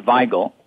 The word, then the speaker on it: Weigel: the correct way to pronounce his name is Weigel, because he was German, but in Pittsburgh, we exercise right to mangle a name if it suits our purposes, so we call him Wiggle.